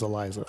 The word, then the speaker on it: Elisa: We could probably believe it's pronounced Eliza. – Eliza? – Yeah. – All right, so it'd be a Z then? – I thought that too, but because when I started typing this out, I spelled it with a Z because I've always heard it pronounced Eliza. – Okay. – But I guess it's with an S.